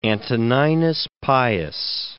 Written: Antoninus Pius

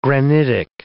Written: granitic

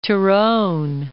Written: Tyrone

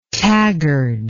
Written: taggard

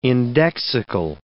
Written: indexical

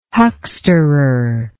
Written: hucksterer

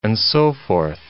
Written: and so forth

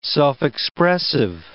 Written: self-expressive

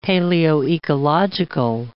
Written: paleoecological

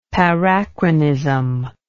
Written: parachronism

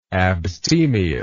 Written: abstemiousness

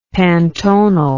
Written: pantonal

